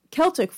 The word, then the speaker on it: Celtic: So the pooka, which is Irish for spirit slash ghost, is primarily a creature of Celtic folklore. Sorry, Celtics is the team. Celtic is the proper pronunciation of everything else.